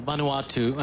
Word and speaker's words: Vanuatu: Vanuatu. Well, I said it wrong, but I said, yeah. But I'll say it right from now on. Vanuatu.